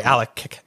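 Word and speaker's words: Alek: So today we're going to be talking about Madonna, Truth or Dare, directed by Alex, I hope I say this right, Keshechian. – Alec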